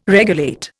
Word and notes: regulate: Indian

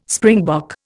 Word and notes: springbok: Indian

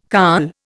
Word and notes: carl: Indian

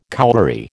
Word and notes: cowberry: American English

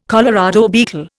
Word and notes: Colorado beetle: Indian

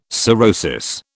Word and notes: cirrhosis: British English

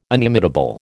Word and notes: inimitable: American English

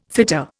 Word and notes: fitter: Indian

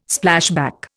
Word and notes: Splashback: Indian

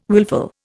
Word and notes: willful: Indian

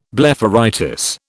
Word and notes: blepharitis: British English

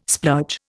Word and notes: splurge: Indian

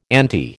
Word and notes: anti: American English

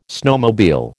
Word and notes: snowmobile: American English

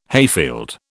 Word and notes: hayfield: British English

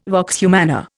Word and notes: vox humana: Indian

